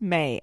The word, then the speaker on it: May: It's pronounced May, as in the month.